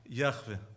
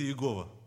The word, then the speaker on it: Яхве: Как это имя звучит? Яхве. Ну, кто-то говорит Иегова, да? То есть по-разному это понимают, по-разному воспринимают. Но, в общем-то, наиболее верно это Яхве. И вот это имя Божье, Яхве, в 118-м псалме встречается 22 раза.